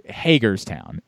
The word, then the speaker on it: Hagerstown: For those that don't know, if you're in a different part of the country, Hagerstown is how you pronounce it.